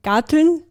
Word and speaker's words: Garteln: Also weil wir vorher im Vorgespräch kurz darauf gekommen sind, genau, persönlich ist Garteln, sagt man das so in Österreich? Habe ich das jetzt gut ausgesprochen? – Ja, genau. Hast du ja, gut.